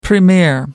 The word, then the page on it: premier: American English